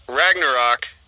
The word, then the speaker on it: Ragnarok: So we'll go Avengers Endgame, Black Panther, Thor, Ragnarok. Did I say that right? – Yeah.